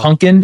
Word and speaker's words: Punkin: Pumpkin? It's pumpkin? – P-U-N-K-I-N.